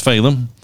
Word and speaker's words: Phelim: and I learned that his first name is pronounced Phelim. I wasn't sure if it was Phelim or Phelim. I've been saying Phelim, and he confirmed that that is.